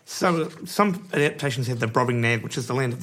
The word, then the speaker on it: Brobdingnag: Brobdingnag.